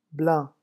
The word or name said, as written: blanc